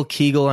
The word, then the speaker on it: Kegel: So is that how you pronounce that? – Yeah. Kegel.